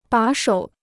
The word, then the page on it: 把手: bǎ shou